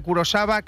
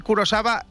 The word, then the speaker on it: Kurosawa: Primero decís siempre que hay una película de Kurosawa que trata el tema más profundamente. No he visto ni una de Kurosawa, pero ni una, cero, cerísimo. Importante decir Kurosawa, aunque se escribe Kurosawa.